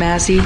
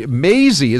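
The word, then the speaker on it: Mazie: And Mazi Hirono. – Mazi? I thought she was going to blow Hirono, but Maisie is pretty easy. I mean, Maisie rhymes with Daisy.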